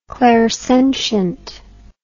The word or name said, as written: clairsentient